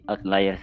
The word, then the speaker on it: Outliers: Judul bukunya agak agak susah nih pronounsnya saya nggak tahu apa cuman saya coba searching di Google Translate ternyata bacanya outliers. Betul gak? – Iya, betul betul.